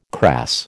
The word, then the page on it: crass: American English